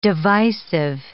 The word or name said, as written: divisive